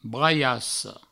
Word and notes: braiassa: braiasso

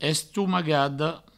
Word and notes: estomagada: Provençal; estoumagado

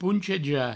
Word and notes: ponchejar: pouncheja

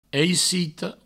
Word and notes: aicito: eicitou

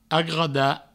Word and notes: agotar: agouta

